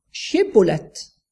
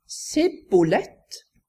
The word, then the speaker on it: Shibboleth: Da sa de til ham, si Shibboleth. Men han sa Sibboleth, for han kunne ikke uttale ordet riktig. Så grep de ham og slo ham i hjel ved Jordans svadesteder.